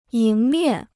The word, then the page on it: 迎面: yíng miàn